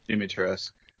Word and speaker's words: Dimitrescu: Dimitrescu. – Why are you just ignoring the last letter? – But because I didn't make up the damn language. I just follow the rules.